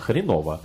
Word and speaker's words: Хренова: Именно, хренова. – А, хренова. – Не хренова, а хренова. – Так. – Специально тут пометили, что ударение на «о».